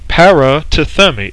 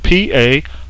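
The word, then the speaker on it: parathemi (παρατίθημι): This word "commend" is taken from the Greek word parathemi. Parathemi. It's spelled parathemi, parathemi, and it means to deposit in a trustworthy place for protection.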